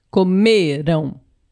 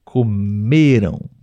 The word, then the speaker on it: comeram: No passado Comeram. Comeram. Comeram. A sílaba tônica está no me. me. – Mas o som é nasal, porque tem a vogal A e a última letra é M.